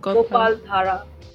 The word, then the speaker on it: Gopaldhara: Gopaldhara. – Thara. – Yes, D-H-A-R-A.